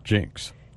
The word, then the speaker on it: jinx: Jinx. – 징크스의 정확한 발음이 Jinx. – 이렇게 하셔야 됩니다.